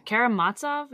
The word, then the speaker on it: Karamazov: Karamazov? Is that how you pronounce it? – Yep.